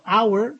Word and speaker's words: hour: Pero hour tiene una H muda.